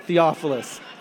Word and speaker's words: Theophilus: most onerous Theophilus. Ooh, I said it right. I kept saying falafel at home. I got it right, Theophilus.